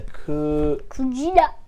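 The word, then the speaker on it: Kujira (くじら): Whale is a Kujira.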